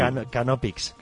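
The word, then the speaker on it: Knoppix: Knopix.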